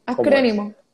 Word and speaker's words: acrónimo: ¿Un acrónimo? ¿Acronimio? – Acrónimo. – Acrónimo. Okay, sí.